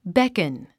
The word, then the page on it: beckon: békən; ベェコ ン